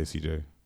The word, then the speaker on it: CJ: But, no, if I do go somewhere and somebody asks for my name, I generally say CJ. Okay. But I have been called TJ, and I'm like, fam, how did you hear a T? Like, those are two completely different syllables, but whatever.